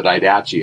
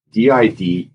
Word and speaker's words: Didache: When I first read it before the internet, I called it the Didache, and no one corrected me because there was no internet. You couldn't, you know, look at these pronunciations and you just go to the library and read the Didache, but it's Didache, and you can Google it.